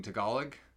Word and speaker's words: Tagalog: Tagalog.